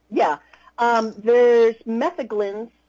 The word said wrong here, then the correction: Metheglin: I think I'm saying that right, which is the spice ones. My Yule Mead is a Metheglin because it basically has spices. – Metheglin. – Yeah, thank you.